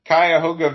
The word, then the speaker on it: Cuyahoga: Yeah, the Cuyahoga Valley Christian Academy. – That's how you say it.